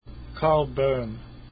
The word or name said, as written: Boehm